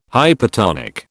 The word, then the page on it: hypertonic: British English